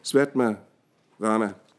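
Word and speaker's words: Svatmarama: I 1400-tallet så kommer, øh, udkommer Hatha Yoga Pradipika, øh, Hatha yoga, øh, lys på Hatha Yoga, skrevet af øh, Svatma Karama, ah, det er ikke udtalt de navne, Svatma Rama.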